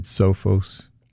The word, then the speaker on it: Sophos: Yeah, ESET, and I think we've also recommended Sophos. – What is it? – Sophos. It's spelled S-O-P-H-O-S. That is a British security company. – S-O-P-H-A-F? – O-S.